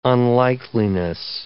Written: unlikeliness

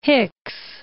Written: Hicks

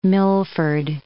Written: Milford

